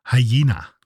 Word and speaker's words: hyena: Wie spricht man denn Hyäne auf Englisch aus? Hyena. Hyena, genau.